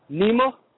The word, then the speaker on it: Nima: Hello, my name's Nima from Baltimore. – Hi. What was your first name? – Nima. – Spell it, please. – N-I-M-A.